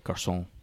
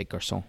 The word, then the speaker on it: garçon: You say it, garçon. You don't really pronounce the N too much. You just say garçon.